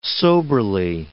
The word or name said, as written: soberly